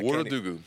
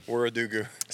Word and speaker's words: Wuradugu: Am I saying this right? No, I'm not. – The Wuradugu. – Wuradugu.